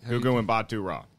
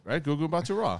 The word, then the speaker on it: Gugu Mbatha-Raw: And then I always pronounce her name wrong. But it's Gugu Mbatha-Raw. Is that how you? – Gugu Mbatha-Raw. – Yeah. So I was right? Gugu Mbatha-Raw. I was not wrong.